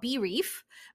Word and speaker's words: Breef: It's called Brief, like literally like your campaign brief, but it's spelled B-R-E-E-F.